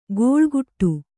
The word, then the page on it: ಗೋಳ್ಗುಟ್ಟು: gōḷguṭṭu